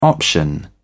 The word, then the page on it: option: British English